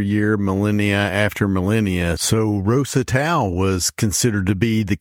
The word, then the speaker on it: Rosetau: It's called Rosetau. – I've always thought that name was interesting. Rosetau. It's spelled R-O-S-E, Rose, and Tau, T-A-U, Tau as in cross.